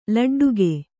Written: ಲಡ್ಡಿಗೆ

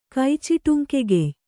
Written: ಕೈಚಿಟುಂಕೆಗೆಯ್

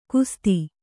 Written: ಕುಸ್ಥ

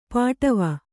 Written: ಪಾಟವ

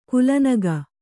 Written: ಕಲನೆಗೈ

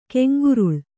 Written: ಕೆಂಗುರುಳು

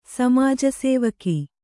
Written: ಸಮಾಜಸೇವಕ

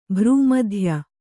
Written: ಭ್ರೂಮಧ್ಯ

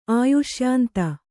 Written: ಆಯುಷ್ಯಾಂತ

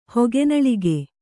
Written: ಹೊಗೆನಳಿಗೆ

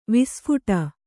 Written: ವಿಸ್ಫುಟ

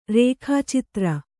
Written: ರೇಖಾಚಿತ್ರ

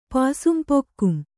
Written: ಪಾಸುಂಪೊಕ್ಕುಂ

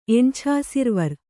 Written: ಎಣ್ಛಾಸಿರ್ವರ್

